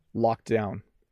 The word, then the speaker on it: locked down: So I overpronounced the second one. Let me say it at my normal speed. Lockdown, locked down. So you hardly hear the D.